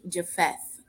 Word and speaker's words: Japheth: Shem, Ham, and Japheth. Did I say that right? Okay. – Yep. There you go.